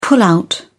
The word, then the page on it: pullout: British English